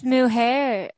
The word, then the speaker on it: mujer: The 2007, J-Lo released the all-Spanish album Como Ava Un Mujer. I'm sorry. – Are you for real? – I can't speak Spanish. – It's Mujer. Mujer. Like, it's not Mujer anyway.